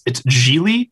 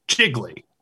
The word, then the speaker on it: Gigli: "My name is pronounced Geely." It rhymes with really. And this is confusing for every character because it's spelled G-I-G-L-I. – It's spelled like jiggly.